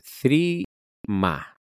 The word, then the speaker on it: Threema: Y después nos quedaría una aplicación más que quiero comentaros, que es esta, la verdad es que la pronunciación es un poco más difícil, que es Threema. Yo lo pronunciaría así. Lo dejaré también en la caja de descripción del podcast. El nombre es T-H-R-E-E-M-A, Threema. Vamos a hablarlo así.